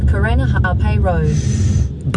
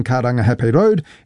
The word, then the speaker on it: Karangahape Road: That was TVNZ's Māori Issues reporter Tianiwa Huri Hanganui driving around Auckland and unimpressed with the navigation voice on the Google Maps app mispronouncing Karangahape Road and other places with Māori names.